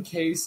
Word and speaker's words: Kase: You can, you can find me at Hayden Kase is on the case.